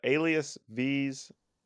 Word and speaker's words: AlisV: That's spelled A-L-I-S-V. So just like Alias, like another name for a person with the letter V at the end, as in violin.